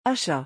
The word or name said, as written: achat